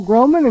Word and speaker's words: Groman: My name is Paul Groman, and Groman is spelled simply G-R-O-M-A-N. G-R-O-M-A-N.